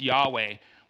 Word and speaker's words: Yahweh: Y-H-W-H, which is pronounced Yahweh, which comes across in our English Jehovah.